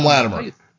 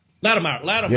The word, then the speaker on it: Latimer: That Littmeyer. How you say it? Tom? – Yeah. Tom Latimer. – Latimer.